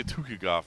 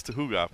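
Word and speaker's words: Tukhugov: Tuhugov. I always say Tukigov. It's Tuhugov.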